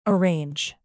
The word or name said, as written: arrange